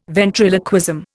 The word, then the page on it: ventriloquism: Indian